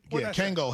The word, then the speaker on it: Kangol: Kangor hats. – Did I say it wrong? Kangol hats. You said Kangor.